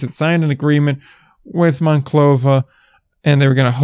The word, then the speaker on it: Monclova: See, I was pronouncing Monclova as Monclova because I thought it was pronounced Monclova. Apparently, it's pronounced Monclova. So a slight difference, but a difference nonetheless.